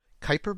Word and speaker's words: Kuiper: Kuiper belt? There, I think I said it right.